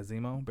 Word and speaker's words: Zemo: Falcon Nemo. Am I saying that right? – That'd be, yeah, Zemo.